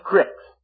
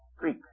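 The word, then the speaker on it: creek: Then it says, and why did Dan remain in ships and Asher continue on the seashore and abode in his creeks? Creeks. Creeks. How do you say that? I always say crick.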